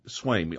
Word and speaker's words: Swaim: Paul Molitor, David Sveam? Am I pronouncing that right? – Dale Swaim? – Swaim. Okay, Ollie Brown or Greg Vaughn? – I'm going to go Ollie Brown.